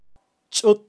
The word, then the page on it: chut: Kaqchikel